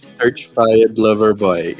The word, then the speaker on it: Certified Lover Boy: Cole, Certi... Como é que fala essa palavra que eu não sei? – Certified Lover Boy. Isso, Certified Lover Boy, eu ia falar completamente errado.